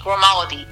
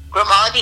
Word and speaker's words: Grimaldi: My Facebook is, I'm going to spell it, it's my name, Brandi Grimaldi. It's B-R-A-N-D-I. That's Brandi with an I, not a Y. Mm-hmm. And Grimaldi is G-R-I-M-A-L-D-I.